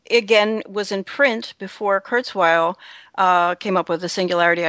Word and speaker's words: Kurzweil: I shy away from Curse, I don't know how to pronounce his name. – Well, it's Ray Kurzweil. Yeah, it's Ray Kurzweil.